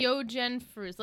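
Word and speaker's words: Yogen Früz: That can't be right. Yogen. How would you pronounce it? – Yogen Fruz.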